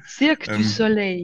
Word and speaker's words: Cirque du Soleil: Cirque du Soleil, ja, genau.